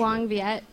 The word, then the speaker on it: Huong Viet: Say the name again, because I'm going to butcher Hoang Viet. – Hoang Viet. – H-U-O-N-G-V-I-E-T.